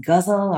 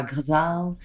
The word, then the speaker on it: ghazal: This poem is in a form called I hear it pronounced ghazal or ghazal, mm-hmm.